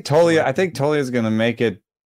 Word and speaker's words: Toglia: It's actually Tolia. The G is silent with Michael Tolia. So I get to have a very exciting minor league event. I thought you said Toglia.